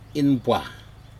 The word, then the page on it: inpuah: Aneityum